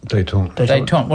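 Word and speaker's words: détente: Detente.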